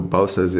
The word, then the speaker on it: Bouças: So Bouças is kind of the weird Portuguese pronunciation.